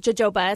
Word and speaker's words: jojoba: And then it's mixed with jojoba oil, J-O-J-O-B-A. A lot of people don't know how to pronounce that one. It's not jojoba, it's jojoba.